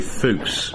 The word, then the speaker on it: Fuchs: Yeah, my name's Terry Fuchs. That's spelled F-U-C-H-S.